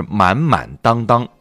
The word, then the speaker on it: 满满当当: 给朋友们说一下这个词的正确读音啊，我在这科普一下。撒贝宁啊，然后呢，另外就是满满当当，一定不要念成满满当当啊。